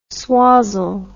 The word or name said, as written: swazzle